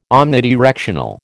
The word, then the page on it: omnidirectional: American English